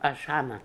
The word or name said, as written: Ashhana